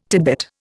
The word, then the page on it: titbit: Indian